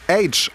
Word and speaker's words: Aitch: Jetzt mit neuer Musik, die kommt vom britischen Rapper H, also ausgesprochen wie das englische H, also das von TH. Ihr checkt, ne? Aber nur ein H wäre als Künstlername vielleicht zu kurz gewesen, deswegen wird es A-I-T-C-H geschrieben.